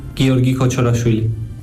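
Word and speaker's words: Giorgi Kochorashvili: El último en llegar, el georgiano Giorgi Kochorafili, o como se pronuncie. – Mi nombre es Giorgi Kochorafili.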